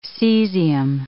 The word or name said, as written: cesium